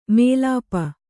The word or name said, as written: ಮೈಲಾಪ